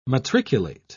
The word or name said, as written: matriculate